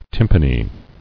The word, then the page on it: Tympany: tym·pa·ny